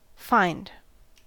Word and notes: fined: American English

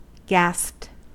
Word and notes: gasped: American English

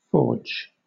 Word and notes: forge: British English

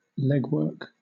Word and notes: legwork: British English

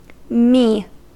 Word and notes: mi: American English

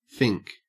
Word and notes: fink: Australian English

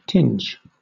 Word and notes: tinge: British English

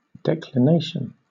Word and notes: declination: British English

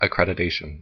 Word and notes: accreditation: American English

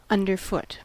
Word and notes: underfoot: American English

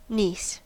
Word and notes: niece: American English

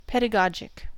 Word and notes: pedagogic: American English

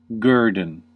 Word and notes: guerdon: American English

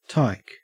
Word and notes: tyke: Australian English

